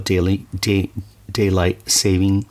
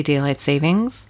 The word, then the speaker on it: daylight saving: The problem is we always add an S to it. – Yes. – But there's no actual S in it. We always say daylight savings.